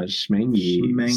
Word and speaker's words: Schmengie: Schmangy. – Schmangy. It's spelled Schmangy. S-C-H-M-E-N-G-I-E. It has a C in it.